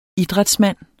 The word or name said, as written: idrætsmand